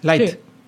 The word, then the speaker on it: Light: Bueno, Die Away están aquí porque van a presentarnos su segundo disco titulado eh, Life Echoes. Eh, mm. ¿Lo digo bien? – Light. Mm. Light eh, pero antes de escucharlo, vamos a informar a nuestra audiencia cuándo, dónde y por qué se forma esta banda.